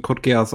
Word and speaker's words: Code Geass: Es wird auch, es wird im japanischen Code Geass ausgesprochen. – Code Geass oh. ist tatsächlich nur so ein deutsches Ding.